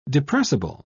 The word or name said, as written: depressible